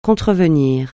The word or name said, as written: contrevenir